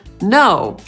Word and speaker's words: know: Kiedy słowo zaczyna się na K, a zaraz po nim jest litera N, to najczęściej tego K się nie wymawia. Tak jest na przykład w słowie know, czyli wiedzieć albo znać. Dlatego mówimy I don't know, a nie I don't know.